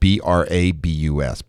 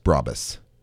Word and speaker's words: Brabus: It's spelled Brabus. Brabus. – B-U-S.